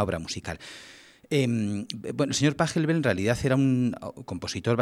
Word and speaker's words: Pachelbel: Todos los que lo habéis escuchado sabéis el nombre, que es el canon, y el autor, que es Pachelbel. Bueno, los puristas dirían que habría que pronunciar Pachelbel, pero nos vale cualquiera de las dos cosas.